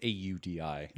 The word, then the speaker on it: Audi: I mean, it's Audi. – Yeah. – Like ow.